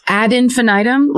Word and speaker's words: ad infinitum: Is that how you say it? – Yes. You need the, it's a Latin phrase. So I think you need the ad part at the beginning.